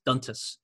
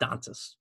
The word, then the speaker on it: Dantas: but apparently that's how she wants her last name to be pronounced, is Dantas, not Dantas.